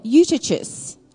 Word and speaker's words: Eutychus: There were many lamps in the upper room where they were gathered, and in a window sat a certain young man named Eutychus. Now, I know that's how you sound it, how you say it, because I Googled it.